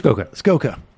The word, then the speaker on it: Skoka: Skoka. – Longo. Skoka. – Rhymes with Lee Iacocca.